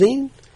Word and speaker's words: zine: Is it pronounced Zine? – Magazine. – That makes sense.